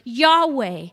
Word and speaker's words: Yahweh: But all ancient scholars, they agree this is most likely how it's pronounced, is this word Yahweh.